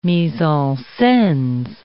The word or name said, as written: mise-en-scenes